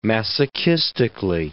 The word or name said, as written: masochistically